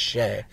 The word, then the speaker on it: Chez: So this show and this bar, it's Chez J or Chez J. – Okay. – I believe it's French. – Okay. – Chez. It's yeah. Pronounced Chez.